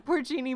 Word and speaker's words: porcini: That's an Italian place, and they serve pork rind mushrooms. – Was that the same pork rind mushrooms? Spinner called them pork rind mushrooms. – Porcini mushrooms. He could not pronounce anything on this thing.